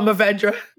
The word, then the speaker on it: Mourvèdre: But if you have something like, I don't know how to, Mourvèdre, how do you pronounce Mourvèdre? – Oh, Mourvèdre, the grape.